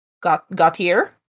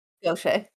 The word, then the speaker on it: Gautier: Hickson was helping Parker get established in the town of Gautier. – Is it G-A-U-T-I-E-R? – Yeah. – Gautier.